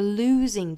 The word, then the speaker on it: losing: 还要注意这个 losing 这个词，我们注意里面是长元音 oo 的发音，还是要注意跟前面这个辅音到元音的过渡啊。前面这个 l 这个口型去过渡到后面的 oo 的口型，不要直接 lose，应该是 losing，口型渐渐聚拢到后面的 oo 的状态。